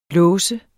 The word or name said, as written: låse